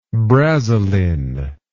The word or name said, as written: brazilin